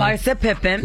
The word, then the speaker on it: Larsa Pippen: Larsa Pippen.